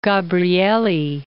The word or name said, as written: Gabrieli